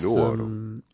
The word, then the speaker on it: Nuoro: I'm in a place called Nuoro, which is Nuoro.